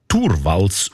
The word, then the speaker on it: Torvalds: Der Linus Torvalds, eigentlich Torvalds ausgesprochen, damals Informatikstudent in Helsinki, er hat die wertvollste Software der Welt namens Linux einfach verschenkt.